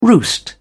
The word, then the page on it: roost: British English